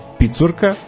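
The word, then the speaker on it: Pițurcă: Tu se već izdvojio kao jedan od glavnih igrača napadač Steaue, Viktor Picurka, tako se izgovara.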